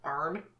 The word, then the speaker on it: Arne: And it's based on, I can't figure out if this is Arne or Arnie. Oh. Johnson. It's spelled A-R-N-E, so Arne.